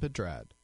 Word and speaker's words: Pedrad: And uh, coming up soon, Nasim Pedral, Pedrad, Pedrad. I don't know how to pronounce that. Max, very familiar with her. Nasim Pedrad.